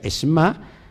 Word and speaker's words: Shema: Fíjense, la palabra Shema se, pone, se, se escribe Shema, pero se pronuncia shma traducida como escucha, puede también significar acepta en tu esencia hasta que vibre en todo tu ser.